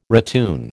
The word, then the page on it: ratoon: American English